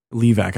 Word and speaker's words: Levac: And one time in the 1970s at the Levac mine, is that how you would pronounce that? – Levac, I'd say. – Levac, okay. It sounds like a weird anglicized version of the Francophone name Levac.